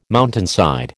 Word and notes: mountainside: American English

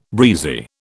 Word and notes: breezy: British English

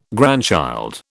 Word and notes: grandchild: British English

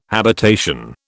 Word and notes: habitation: British English

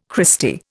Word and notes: Christy: Indian

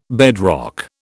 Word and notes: bedrock: British English

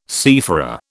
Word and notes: seafarer: British English

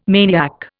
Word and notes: maniac: Indian

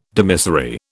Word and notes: dimissory: British English

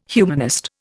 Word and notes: humanist: Indian

